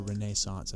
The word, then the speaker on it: Renaissance: the Enlightenment, the Renaissance, or Renaissance as it's properly pronounced, fun fact.